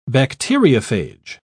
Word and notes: bacteriophage: British English

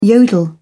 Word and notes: yodel: English; British English

